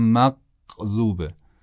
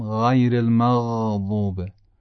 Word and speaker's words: المغضوب: بله اون اولی قاف بود که قابل امتداد نبود، میگفتم مقذوبه مقذوبه، اما دومی رو درست گفتم، گفتم غیر المغضوبه. هم غیر، ببینید غیر، غیر خیلی نرم تلفظ میشه، نه غیر المغضوبه.